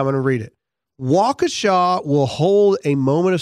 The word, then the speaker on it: Waukesha: Uh, do you know how to pronounce the town's name? Waukesha. Waukesha. Thank you.